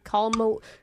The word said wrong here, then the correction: Kalamata: Say it right. – K-A-L-A-M-A-T-A. – Wow. – Kalamata. Oh, Kalamata.